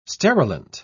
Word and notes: sterilant: British English

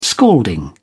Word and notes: scalding: British English

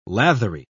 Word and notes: lathery: British English

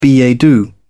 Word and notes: billet doux: British English

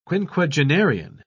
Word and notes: quinquagenarian: British English

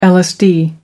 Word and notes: lsd: American English

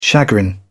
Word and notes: chagrin: British English

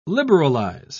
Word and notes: liberalise: British English

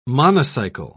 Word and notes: monocycle: British English

